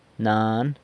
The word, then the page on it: nan: sanlucasquiavini